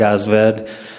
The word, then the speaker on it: Aasved: Mr. Chairman and members of the committee, my name is Craig Osved. That is spelled A-A-S-V-E-D.